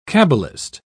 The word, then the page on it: cabalist: British English